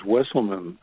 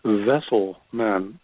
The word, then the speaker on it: Wesselman: And he said, you know, the ancestors asked me what your name was, and I told them, your name is Wesselman, Hank Wesselman. And they told me, I wasn't pronouncing your name right. They said you're actually pronounced Vesselman, that you're a vessel, like the canoe.